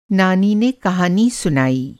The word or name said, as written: नानी